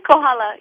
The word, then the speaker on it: Kohala: Because you were born in, how do you say it, Kohala, Hawaii? – Kohala, yes.